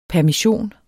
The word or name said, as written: PERMISSIONENS